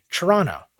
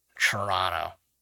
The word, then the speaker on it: Toronto: I got to go to the screening in Toronto, and I say Toronto. I never say that as just a regular citizen of Canada. We say Toronto. All the letters are missing. Basically, the way I pronounce it, it would be spelled like C-H-U-R-R-O-N-N-O. Toronto. But yes, for more cultured people than ourselves, Toronto.